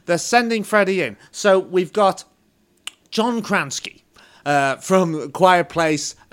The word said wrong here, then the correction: Krasinski: Krasinski. – John, is that how you say it? Kransky? Krasinski? – Krasinski, yeah.